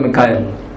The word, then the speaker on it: Mikhail: I would prefer to call him Mikhail. Right? Your Malayalam Bible has the right translation, right pronunciation. It's Mikhail.